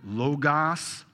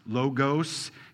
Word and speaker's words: logos: What you need to know is John, when he talks about the Word, it's the Greek word logos, logos. You've heard it pronounced other ways.